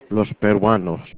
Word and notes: peruanos: Spanish

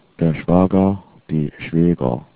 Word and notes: schwager: german

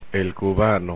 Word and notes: cubano: Spanish

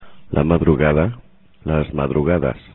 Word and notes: madrugada: spanish

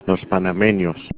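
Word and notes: panameños: Spanish

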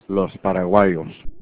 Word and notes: paraguayos: Spanish

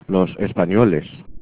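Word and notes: españoles: Spanish

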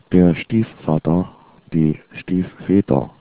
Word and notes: stiefvater: german

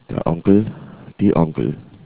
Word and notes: onkel: german

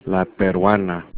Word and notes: peruana: Spanish

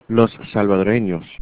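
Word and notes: salvadoreños: spanish

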